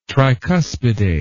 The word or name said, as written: tricuspidate